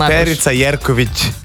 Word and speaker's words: Perica Jerković: Lahko grete na ta prav stand-up v parku Žibartje ob 7 zvečer, Perica Jerković, Perica Jerković. – Ta mehki, če ne znam izgovoriti. – Perica Jerković. – Eto. – No, bravo.